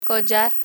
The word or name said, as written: collar